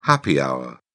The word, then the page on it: happy hour: British English